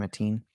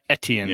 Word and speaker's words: Etienne: Etienne? – Etienne.